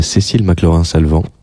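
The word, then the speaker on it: Cécile McLorin Salvant: continuer avec euh, ces petites surprises que la vie nous réserve, en écoutant parfois TSF Jazz, où on tombe sur euh, Cécile McLaurin-Salvant, ça se prononce bien à la française, car elle est française,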